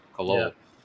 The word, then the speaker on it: Khalil: because Khalil is not right, it's Lil. – It's Khalil. – Yeah. Khalil.